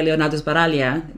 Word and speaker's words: Leonardo Sbaraglia: And I won't talk much about him, because there's more to the character than meets the eye, and it's played by Leonardo Sbaraglia. I think that's how you say his name, right? – Yeah.